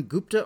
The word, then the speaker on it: Gupta: Is it Arun Gupta or Gupta? – Gupta. – Gupta. Okay, very good.